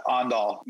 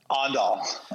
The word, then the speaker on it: Aandahl: Yeah, I was like, you totally butchered that. Aandahl. – Pronounce it for us. Go ahead. – Aandahl. – Aandahl. – It's very right. – difficult. – That's exactly right.